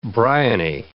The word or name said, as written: bryony